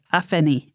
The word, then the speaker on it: Afeni: And so we're going to examine today Afeni.